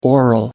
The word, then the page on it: aural: American English